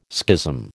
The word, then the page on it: schism: American English